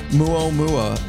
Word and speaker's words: Muomua: Muomua. M-U-O-M-U-A. Oh, yep. – Is that Hawaiian? – You know, I think it is, but he's from Pittsburgh. Doesn't mean he's not Hawaiian. – Right. – Samuel Muomua.